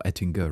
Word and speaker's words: Ettinger: So it was when I first featured the Ettinger wallet, or Ettinger rather, realized I've been pronouncing it wrong the entire time.